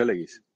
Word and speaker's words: legis: es un término legis, perdón, se pronuncia legis, es un término jurídico.